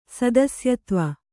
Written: ಸದಸ್ಯತ್ವ

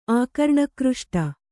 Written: ಆಕರ್ಣಕೃಷ್ಟ

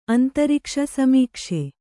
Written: ಸಮೀಕ್ಷೆ

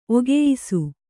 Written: ಒಗೆಯಿಸು

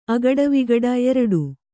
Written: ಅಗಟವಿಗಟ

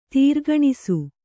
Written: ತಾರ್ಕಣಸು

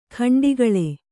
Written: ಗಂಡುಗೊಳ್ಳು

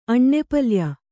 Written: ಅಣ್ಣೆಪಲ್ಯ

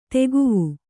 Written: ದಿಖಾವು

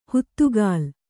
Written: ಹುತ್ತುಗಾಲ್

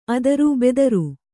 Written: ಅದುರು

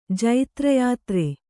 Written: ಜೈತ್ರಯಾತ್ರೆ

